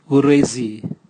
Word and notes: guresi: Raga

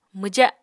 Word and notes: mwja: Apatani

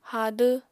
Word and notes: hadw: Apatani

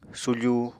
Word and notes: sufu: Apatani

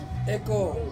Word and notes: ko: Ansus

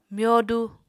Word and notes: myodu: Apatani

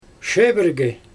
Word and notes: ʃebørge: Xyzyl

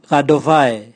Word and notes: gadova: Raga

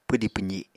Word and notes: pwdi pwzi: apatani

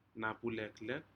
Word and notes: napuleklek: Aneityum

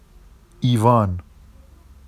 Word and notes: ivɑn: Vafsi

